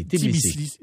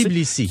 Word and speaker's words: Tbilissi: On a découvert dans des fouilles archéologiques, dans un village néolithique, à 40 kilomètres au sud de Tisli. – Tbilissi. – J'arriverai jamais à le prononcer. – Tbilissi.